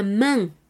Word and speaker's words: main: La bra, el antebrazo, A-V-A-N-T, e B-R-A-S, el antebrazo, igualmente gauche ou droit. La main, la main. Es cierto que podemos escuchar la pronunciación me en algunos espacios francófonos. Nos referimos a la mano, M-A-I-N.